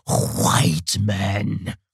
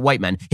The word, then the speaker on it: white: And by the way, if you don't pronounce the H before the W, you're doing it wrong. It's not white men. It's white men, like Mr. Musk.